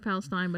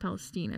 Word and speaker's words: Palestine: Looks like Palestine, but it's yeah. pronounced Palestine, I believe.